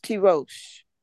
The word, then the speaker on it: tirosh: It's a Hebrew word and it's pronounced tirosh.